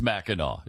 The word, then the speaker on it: Mackinac: It's spelled, why do they spell it Mackinac when it's Mackinac? It doesn't make any sense.